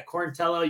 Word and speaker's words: Quarantillo: Some of them spell it a little differently with an E, because like when they were coming over to Ellis Island, they didn't pronounce it the right way. But yeah, Quarantillo, you say it great.